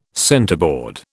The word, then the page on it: centerboard: British English